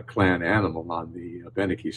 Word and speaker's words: Abenaki: Abenaki. – The emphasis is usually on the third syllable from the end, Abenaki.